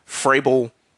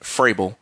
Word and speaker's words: Vrabel: And once again, that is Steichen for the Colts, Ryan's for the Texans, Vrabel. Even though it's spelled V-R-A-B-L-E, it's pronounced like an F, Vrabel.